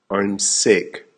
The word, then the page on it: I'm sick: American English